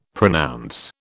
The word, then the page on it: pronounce: British English